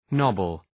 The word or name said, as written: nobble